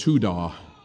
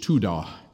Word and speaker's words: todah: Thanksgiving, the definition in Hebrew, is this funny little word called todah, pronounced or written out T-O-W-D-A-H, todah.